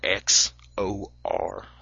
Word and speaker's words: XOR: The next gate is the XOR gate, which is the letter X O R. So XOR is how we pronounce it or how I pronounce it.